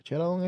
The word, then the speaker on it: Sheeran: Cuando regresemos al próximo pop, voy a recomendar uno que de Ed Sheeran. ¿Sheeran es el? – Sheeran.